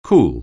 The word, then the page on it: cool: anglais